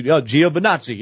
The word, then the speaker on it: Giovinazzi: It's Antonio Giovinazzi, Giovinazzi.